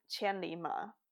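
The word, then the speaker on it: Qianlima (千里马): But actually, in Chinese classics, the name is pronounced as Qianlima, which means a thousand-mile horse.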